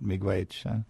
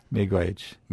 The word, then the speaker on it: Miigwech: Miigwech. Miigwech. – Miigwech. – Miigwech. And you can intensify it by saying chi miigwech, which means thanks a lot.